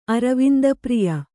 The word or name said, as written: ಅರವಿಂದಪ್ರಿಯ